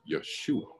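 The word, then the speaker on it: Yeshua (ישוע): But how the Jews would have pronounced it was Yeshua.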